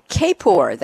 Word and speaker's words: Kapor: It's Mitchell David Kapor. That's the way he pronounces his name, K-A-P-O-R.